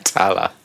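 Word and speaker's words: Tallaght: Tala. – How do you pronounce that? Tala. – The G, the H, and the T are silent.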